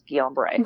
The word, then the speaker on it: Guillain-Barré: I'm wondering, does I already forgot how to pronounce Guillain-Barré. – Guillain-Barré. Yeah. Does Guillain-Barré. – Some people say Guillain-Barré, but I've had 5 neurologists confirm that's Guillain-Barré.